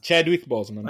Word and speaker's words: Chadwick Boseman: La Marvel ha dato veramente tantissimo spazio a Pantera Nera, e molte delle qualità di Pantera Nera si potevano vedere in Bojwick, ora non la pronuncia, ovviamente Chadwick Boseman.